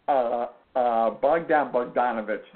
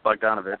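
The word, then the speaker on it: Bogdan Bogdanović: Serbia, which features a bunch of people that you especially can pronounce better than I can, but it includes Bogdan Bogdanovic. See, my first attempt at this name was horrible. Bogdan Bogdanovic. There we go. – Yeah, Bogdanovic.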